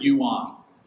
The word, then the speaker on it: Yuan: Ewan, and actually pronounce it Yuan, Y-U-A-N, Yuan.